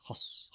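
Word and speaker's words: Höss: Because it's H-O-S-S, Huss.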